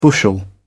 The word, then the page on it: bushel: British English